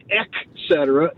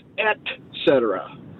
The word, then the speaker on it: et cetera: There is no C after the E, so it's not et cetera, it's et cetera.